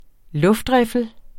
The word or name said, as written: luftriffel